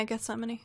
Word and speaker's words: Gethsemane: Before Jesus went to the cross, he prayed in, I never say this right, Gethsemane? – Yeah, Gethsemane.